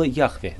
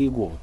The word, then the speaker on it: Яхве: Если вы вспомните, имя было Яхве, да? Ну, и неправильно произносить, как Иегова.